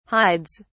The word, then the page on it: hides: British English